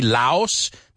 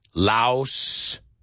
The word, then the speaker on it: Laos: The country Laos that is near Vietnam and Cambodia. Laos. Well, that, guess what? They don't pronounce that S. It's Lao.